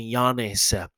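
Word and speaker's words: Giannis: 没有 Wacky Bucks 的主将就是那个，就是那个那个那个那个什么，那个那个字母超级宇宙世界霹雳无敌长的，然后那个 G 不要发音哦，那个 G，所以他是念 Yanis and I talk about Yanis and talk about，so Yanis Yanis Yanis Yanis Yanis，OK，so Yanis and talk about。 Yanis Antetokounmpo，这个是希腊的名字哦。 Yanis Antetokounmpo，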